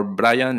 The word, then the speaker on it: Brayan: So my name is Brian with two A's, B-R-A-Y-A-N, or Brayan in Spanish, because my mom couldn't speak English. She pronounced Brian in Spanish. So it's Brayan.